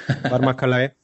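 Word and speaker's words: Varma Kalai: You pronounced that very, very nicely, very well, Vama Kalai.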